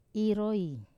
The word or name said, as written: roi